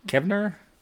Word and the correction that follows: Kavner: Julie Kavner.